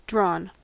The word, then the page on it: drawn: American English